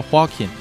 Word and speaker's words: Joaquin: 这个 J 呢，呃，发音是 H 那个音，所以那个 Joaquin 它是它这个发音叫 Joaquin，就是华金，呃，Guzman Lara 呢是 Guzman 是他的父姓，啊，Lara 是他的母姓。